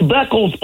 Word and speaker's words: Beckles: Since we're just mispronouncing names, it's Beckles, Playboy, BECKLES.